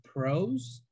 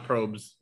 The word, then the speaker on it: Pros: So also before we start, I do want to send a shout out to Charity, who won the Apple AirPod Pros. Is that how you say it? I don't know. They're earphones. So not probes. Pros.